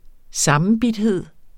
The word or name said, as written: sammenbidthed